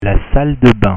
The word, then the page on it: la salle de bain: French